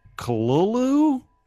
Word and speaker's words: Cthulhu: Cthulhu is pronounced Cthulhu? That's what they're saying, which is KHLUL hyphen LOO.